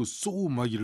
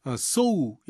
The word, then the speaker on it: sow: SEW 또 SOW 이거 읽으라 그러면 못 읽는 학생들이 많아요. 막 쓰유 막 이렇게 읽고 쏘막 이렇게 읽고 그러는데 둘다 발음이 똑같아요. 쏘 이렇게 돼요. 오우 발음입니다. 쏘 SEW나 SOW는 다 쏘 이렇게 하는데요. SEW는 바느질하다. SOW는 씨를 뿌리다 이런 단어예요. 외우기 쉬워요. So, SOW의 O가 동그란 C라고 생각하시면 돼요. 근데 이게 발음이 똑같은 게 주의사항이죠.